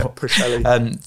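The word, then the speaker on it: Pwllheli: So, I mean, lots of people from the FIC used to come to, I'm going to say this wrong, I realise, Pwtheli. – Yeah, Pwtheli.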